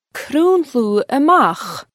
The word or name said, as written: Crùnludh a-mach